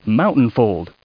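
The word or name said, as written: mountain fold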